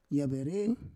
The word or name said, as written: yavere